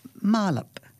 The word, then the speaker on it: marlop: Pronounced marlop, M as in member, R as in car, L as in lonely, A as in alone, and P as in paper.